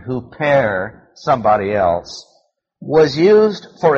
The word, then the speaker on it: huper: The word "for" is the Greek word "huper." Spell it H-U-P-E-R.